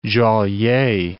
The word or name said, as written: Joliet